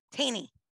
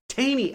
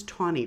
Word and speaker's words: Taney: Taney. Okay. I always heard it was Tawney, Roger Tawney, like the color. I've heard it also as Taney. So we're going to be talking two different pronunciations, but the same person when we get to him.